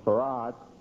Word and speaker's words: Fard: When black Muslims started to be attracted to that area, I believe soon after that, a man by the name of Farad, spelled F-A-R-D in the Encyclopedia Britannica, and I believe in some other encyclopedias and perhaps dictionaries too, you'll find his name, spelled F-A-R-D, it's pronounced F-A-R-A-D, Farad.